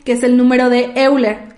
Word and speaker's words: Euler: Ah, es que muchos le dicen Euler, Euler, Euler, Euler. O sea, se escribe E-U-L-E-R, Euler.